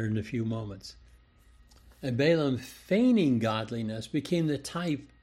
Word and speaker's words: Balaam: And of course, this is what we're going to be talking about today with the error of Balaam, or Balaam in the English, as it's usually pronounced. In the Hebrew, it's Balaam. He learned from Eve, who set Cain up with the illusion that he was Messiah.